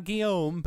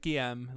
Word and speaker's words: Guillaume: Yeah, it's not Guillaume, but Guillaume, like A-I-M-E.